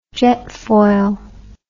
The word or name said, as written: jetfoil